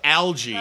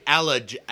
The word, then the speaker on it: algae: Oh, Yohei mispronounced algae. – Algae. – He's like allergy.